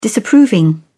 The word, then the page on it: disapproving: British English